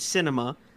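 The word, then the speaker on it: Sinema: Arizona Senator's name is pronounced cinema,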